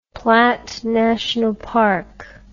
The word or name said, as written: Platt National Park